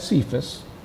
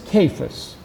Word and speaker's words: Cephas: And now many people pronounce this as Cephas, but it is Cephas, which was the pronunciation at the time, which is translated for Peter as rock, boulder, or stone.